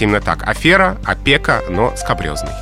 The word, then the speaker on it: скабрёзный: Скабрёзный, именно так надо говорить это слово. Произношение обусловлено происхождением, как это часто бывает. Слово пришло к нам из французского языка, а там оно восходит к латинскому «скабросус» — «шершавый, грубый».